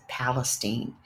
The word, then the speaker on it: Palestine: And yes, the locals pronounce it Palestine. The cleanup is ongoing, and it's expected to cost $400 million.